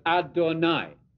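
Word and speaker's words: Adonai (אֲדֹנָי): So El Shaddai rhymes with Adonai.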